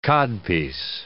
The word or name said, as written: codpiece